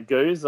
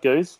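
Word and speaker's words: gose: Well, we've had a couple of lambics and a goose. I'm probably going to pronounce that wrong. Anyway, no, you got it. – Got it, goose.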